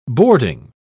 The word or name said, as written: boarding